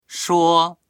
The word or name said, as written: shuo1